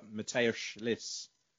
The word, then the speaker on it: Mateusz Lis: Mateusz Lis.